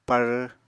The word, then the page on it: pár: apatani